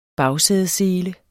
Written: bagsædesele

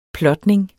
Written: plotning

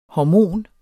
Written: hormon

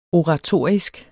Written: oratorisk

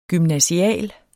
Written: gymnasial

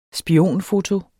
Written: spionfoto

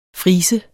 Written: friser

